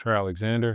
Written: Trey Alexander

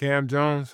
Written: Kam Jones

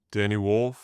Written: Danny Wolf

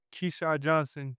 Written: keshad JOHNSON

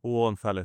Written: Hugo Gonzalez

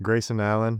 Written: Grayson Allen

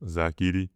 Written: zach EDEY

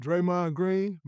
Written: Draymond Green

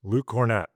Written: Luke Kornet

